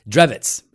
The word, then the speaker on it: Drewitz: Drewitz. It's pronounced Drewitz. – Oh, did I just mispronounce? – No, because it's like a German name, and he's like, but even though he's like super American, he's like, no, it's Drewitz.